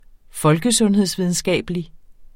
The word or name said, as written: folkesundhedsvidenskabelig